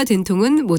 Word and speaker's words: 된통: 그런데 되게와 된통은 모두 첫 번째 음절을 길게 발음하는 반면에 되우는 짧게 발음한다는 점에 차이가 있습니다.